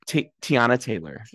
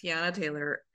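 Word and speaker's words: Teyana Taylor: Tiana Taylor. – Tiana Taylor.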